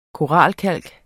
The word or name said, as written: koralkalk